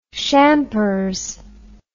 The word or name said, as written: shampers